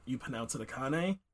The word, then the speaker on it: Akane: Her name, that's, like, stylized, but you pronounce it Akane.